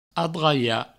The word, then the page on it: adraiar: adraia